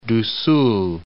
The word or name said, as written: Paraiba do Sul